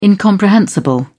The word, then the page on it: incomprehensible: British English